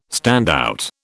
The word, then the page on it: standout: British English